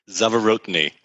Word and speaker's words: Zavorotny: Zavorotny.